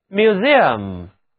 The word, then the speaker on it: museum: 在这个词当中，字母 E-U 组合读作 ear，请跟我读 museum museum。